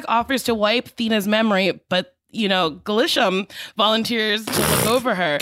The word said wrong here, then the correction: Gilgamesh: Gilgamesh. Glisham. Gilgamesh. – Gilgamesh volunteers to, honestly, it's spelt the way it's pronounced.